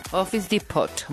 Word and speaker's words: Office Depot: Y Office Depot me mandaron un mensaje muy importante para todos ustedes. ¿Lo dije bien? – Office Depot, muy bien.